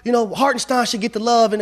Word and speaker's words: Hartenstein: Yeah, I said it correctly. I ain't say Frankenstein. I said Hartenstein because the boy got heart.